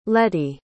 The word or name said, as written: lettie